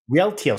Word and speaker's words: Rialto: Rialto. Was that correct? – Rialto. – Sorry. That's the emphasis on the wrong syllable.